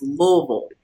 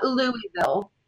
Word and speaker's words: Louisville: A really good friend of mine is from Louisville, and she says, say it like you have a mouthful of rocks or marbles. So it's Louisville. – Right. It's not Louisville.